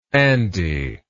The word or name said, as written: Andy